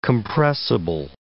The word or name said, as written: compressible